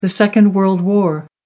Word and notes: second world war: American English

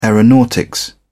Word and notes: aeronautics: British English

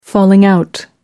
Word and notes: falling out: American English

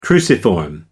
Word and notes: cruciform: American English

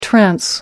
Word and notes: trans: American English

